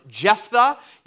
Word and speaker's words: Jephthah: That's how his name is pronounced, Jephthah. You can think of it as Jeph the Great or Jeph the Confused or Jeph the Not So Great.